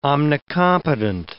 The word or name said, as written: omnicompetent